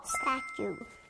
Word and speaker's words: statue: 우 소리가 나는 다음 단어는요. 우리가 박물관 가면 많이 볼 수 있는 조각상이에요. 조각상 영어로 뭐였죠? – Statue. – That's right, statue.